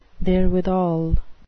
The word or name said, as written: therewithal